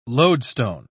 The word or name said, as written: lodestone